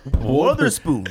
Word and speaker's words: Wotherspoon: Is it Wetherspoon or Wetherspoon? – Wetherspoon. – Okay, Wetherspoon. – Wetherspoon. – But they spelled with an O, right? W-O? – It's Wother. – Wotherspoon. – They put an extra R in there. – Wotherspoon. – You wash your clothes too? – Wotherspoon. – No, W-O-T-H.